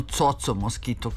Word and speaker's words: Coco Mosquito: U pesmi Na plaži slušali smo zagrebački bend Jinx, koji je osnovao gitarista Coco Mosquito, odnosno Coco Mosquito, kako on kaže da treba da se izgovara,